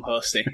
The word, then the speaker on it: Hosty: Yeah, I enjoy being Tom Hostey. Yeah, it was. I did a competition a few weeks ago, a weightlifting competition, and they were pronouncing my name Hostley Cundell.